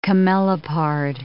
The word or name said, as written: camelopard